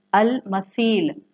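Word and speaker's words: अल मसील (المثيل): The third level is अल मसील. मिसाल से है जिसको आप अरबी में सा को सॉफ्टली प्रोनाउंस करते हैं. अल मसील, मीम, सा, या, लाम.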